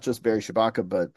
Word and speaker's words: Shabaka: What is the, I might be getting his name wrong. Barry Shabatka Henley? – Yes, that's a good scene. Yeah, Barry Shabaka Henley.